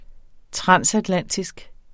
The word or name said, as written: transatlantisk